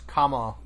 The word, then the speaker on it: Kamala: So, So the vice president, you say her name like the punctuation first. Kamala.